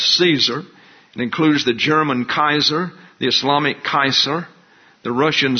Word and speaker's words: Caesar: so it's pronounced Kaiser.